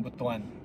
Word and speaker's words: Butuan: Runner up was, how do you say this? Butuan. – Butuan. – Yeah, Butuan was the runner up for the first one.